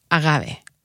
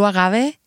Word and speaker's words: agave: Okay, from a 100% blue agave, Tequilana Weber, which is a type of agave. – Okay, when you say agave, you're saying agave. – Agave. – A-G-A-V-E. – A-G-A-V-E. – Okay. – With the correct pronunciation, our Americanized version. – Americans say agave.